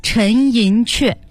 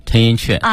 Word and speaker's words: 陈寅恪: 这个里头，其实陈老师也写到了，比如说清华四大导师之一的陈寅恪。 – 陈寅恪啊，你看读陈寅恪，可是你要是翻字典的话，这个字只有一个读音，念克。 – 一个竖心，一个个人的，一个个个的个。 – 对，但是老先生自己说我就叫陈寅恪，所以大家就都跟着叫陈寅恪。